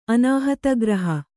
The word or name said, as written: ಅನಾಹತಗ್ರಹ